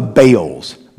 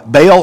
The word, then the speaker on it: Baal: The primary God that the Israelites were tempted to worship were a class of Canaanite gods called the Baals, B-A-apostrophe A-L, Baal, or sometimes pronounced Baal.